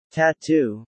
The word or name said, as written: tattoo